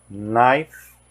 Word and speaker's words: knife: Todo KN tem som de N, ou seja, o K é mudo. Knife, ni, nit, no, knock, night.